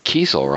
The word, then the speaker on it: Kiesel: So Kiesel, right? – Kiesel, yeah. You know, like I don't lose sleep if someone says Kessel.